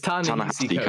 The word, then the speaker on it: Ta-Nehisi: It's Ta-Nehisi Coates.